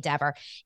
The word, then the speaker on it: Dever: So some people say Deaver, some people say Dever.